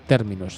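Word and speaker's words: T3RMINUS: se escribe una T, un 3 en número, una R, una M, una I, una N, una U y una S, Terminus se pronuncia.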